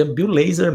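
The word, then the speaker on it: Bill Lazor: Bill Laser que eu descobri hoje que o nome dele é, se pronuncia Bill Laser mesmo.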